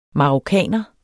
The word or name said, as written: marokkaner